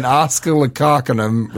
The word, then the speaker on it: Oscillococcinum: Or should I say an oscillococcinum restaurant? – That's how it's pronounced, actually. But I got to tell you, buddy.